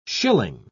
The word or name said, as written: schilling